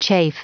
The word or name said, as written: chafe